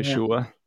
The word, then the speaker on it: Yeshua: well, if you look at the the original pronunciation of Jesus' name, it sounds a lot more like Joshua than Jesus. – It's yeah. Yeshua.